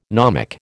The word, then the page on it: gnomic: American English